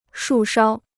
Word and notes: 树梢: shù shāo

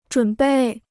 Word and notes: 准备: zhǔn bèi

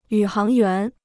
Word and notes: 宇航员: yǔ háng yuán

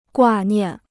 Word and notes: 挂念: guà niàn

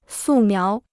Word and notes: 素描: sù miáo

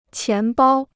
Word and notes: 钱包: qián bāo

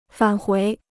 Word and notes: 返回: fǎn huí

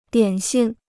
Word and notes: 点心: diǎn xin

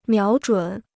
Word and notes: 瞄准: miáo zhǔn

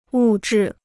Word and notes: 物质: wù zhì

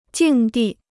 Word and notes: 境地: jìng dì

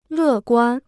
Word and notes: 乐观: lè guān

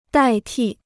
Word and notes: 代替: dài tì

